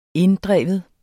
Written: INDDREV

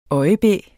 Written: øjebæ

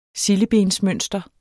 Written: sildebensmønster

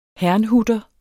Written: herrnhuter

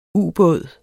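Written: Ubåden

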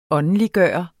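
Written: åndeliggørende

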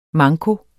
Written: manko

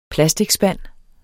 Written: plasticspand

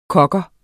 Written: kogger

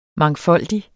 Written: mangfoldig

